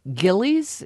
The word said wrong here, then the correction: Jilly's: Is that a custard stand? – Jilly's. – Yeah. I'm sorry, Jilly's,